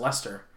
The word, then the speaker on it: Leicester: Leicester. I got it. It's all good. Leicester. Okay, it's pronounced Leicester.